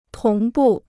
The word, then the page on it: 同步: tóng bù